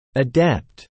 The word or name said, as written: adept